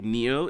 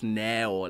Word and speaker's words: Nao: What does N-A-O mean? – Okay. Well, Nao, actually, it came up, it's still pronounced Nao, even though it's spelled N-A-O. – Yeah. – It came out, my parents didn't want me to have Facebook or any social media, so I changed my name to sort of like fool them and trick them. It didn't work at all. It didn't work at all. Also, I mean, my thought behind it was like, it's how you say it. I despise being called Neo.